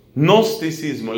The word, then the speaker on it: gnosticismo: Gnosticismo, la palabra gnosticismo se escribe con una G al principio, como decir gnosticismo. Y es con la letra C, gnosticismo.